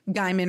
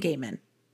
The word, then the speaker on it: Gaiman: Rhymes with Cayman. – So I've been saying it right, I think. I think I've been saying Gaiman.